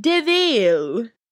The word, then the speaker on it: Deville: It was the dude in the movie, one of her best friends, was like, it's spelt devil, but it's pronounced Deville.